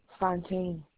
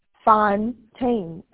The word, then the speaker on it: Fontaine: Teresa Fontaine. – Teresa, is that T-H-E-R-E-S-A? – Mm-hmm. – I'm something. Can you spell that for me? – Fontaine. F-O. It smells like fountain. It's just Tane. T-A-I-N-E at the end. – Thank you. So that is F-O-U-N-T-A-I-N? – Mm-hmm.